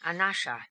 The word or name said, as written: anasha